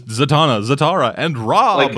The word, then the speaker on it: Zatara: Zatara.